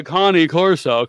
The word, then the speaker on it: Cane Corso: But for these, for the owners, like, oh, my Cane Corso, come over there. – Yeah, that's the right way to say it.